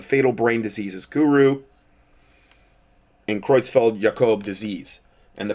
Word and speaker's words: Creutzfeldt Jakob: Um, Creutzfeldt Jakob is, I believe, the proper pronunciation. Disease, or CJD. Creutzfeldt Jakob.